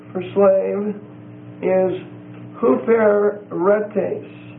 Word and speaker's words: huperetes: Or might be huperetes. H-U-P-E-R-E-T-E-S. The first E and the last E are long E's, they're eights. So, long E for the first E, long E for the last one. H-U-P-E-R-E-T-E-S.